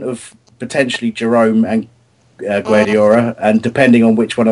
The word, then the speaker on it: Guedioura: I can't pronounce it now, but is it Ad- Adlène Guedioura? Yeah, Guadiara.